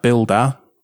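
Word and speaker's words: Buildah: You have Builder, Build-A-H is how it's spelt, and that's designed to just build containers.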